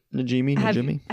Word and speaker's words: Najimy: Have you met the three main actors, Bette Midler, Sarah Jessica Parker, and Kathy Najimy? I'm pretty sure I'm pronouncing that correctly. – Yeah. Najimy.